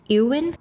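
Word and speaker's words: Éowyn: And the character, I hope I'm pronouncing the character's name correctly, Eowyn.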